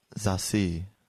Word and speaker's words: Zassi: So the second Z sounds like an S, and there's an inflection on the C. Zassi. – Zassi.